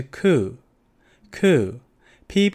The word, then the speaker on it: coup: 缅甸军方发动政变，政变就是 coup，coup，p 不发音，coup，coup。